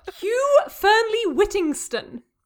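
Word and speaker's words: Hugh Fearnley-Whittingstall: Hugh Fernley Whittingston. – Hugh Fernley Whittingston.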